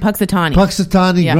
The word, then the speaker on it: Punxsutawney: Punxsutawney Groundhog Club Inner Circle.